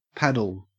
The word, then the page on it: paddle: Australian English